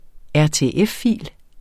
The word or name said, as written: RTF-fil